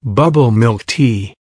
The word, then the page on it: bubble milk tea: American English